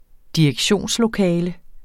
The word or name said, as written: direktionslokale